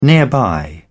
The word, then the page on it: nearby: British English